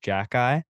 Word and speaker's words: Xhekaj: His nickname is Wi-Fi because his last name is spelled like Xhekaj and it's pronounced Jack Eye.